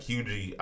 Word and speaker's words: Huger: And, like, yeah, it's Hugie, but when you read it, it's spelled Huger. H-U-G-E-R, I don't know. – That's that South shit. – Yeah, I don't know y'all. So yeah, the English dictionary. – There you go. – The R is silent.